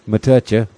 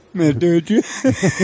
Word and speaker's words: Matoucha: It's spelled M-A-T-O-U-C-H-A. Matucha. – Matucha.